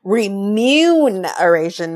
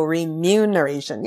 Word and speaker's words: remuneration: It's remuneration, not remuneration. So remuneration.